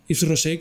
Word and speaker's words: Yves Rocher: Yves Rosé, que bueno, ya lo estuvimos hablando el otro día, ¿Lo pronuncio bien? ¿Lo estoy pronunciando bien? Yo siempre digo lo mismo, digo, ¿pronuncio bien las ah, cosas o no? Lo, pronuncias bien, porque aquí hay margen, se, permite se permite varias pronunciaciones.